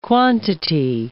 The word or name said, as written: quantity